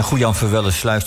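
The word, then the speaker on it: Goejanverwellesluis: Goejan Verwellesluis, zeg ik het goed, Jos? – Ja, je zegt het heel goed. En Goejan Verwellesluis, dat is natuurlijk dé plek waar je moet zijn als het om opstand in Nederland gaat.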